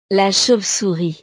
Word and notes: la chauve-souris: frances

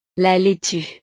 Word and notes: la laitue: French

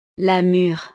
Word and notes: la mure: frances

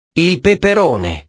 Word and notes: il peperone: italiano

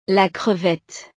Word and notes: la crevette: frances

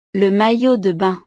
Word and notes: le maillot de bain: frances

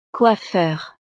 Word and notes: coiffeur: frances

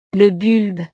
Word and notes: le bulbe: frances

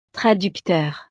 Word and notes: traducteur: frances

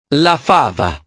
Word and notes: la fava: italiano

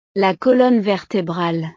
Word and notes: la colonne vertebrale: frances

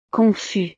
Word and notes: confus: frances

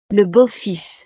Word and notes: le beau fils: frances

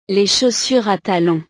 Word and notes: les chaussures a talon: frances

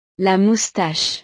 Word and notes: la moustache: frances

